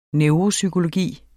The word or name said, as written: neuropsykologi